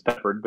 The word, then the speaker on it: Peppard: It's Peppard, but you got real close.